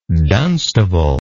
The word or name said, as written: Dunstable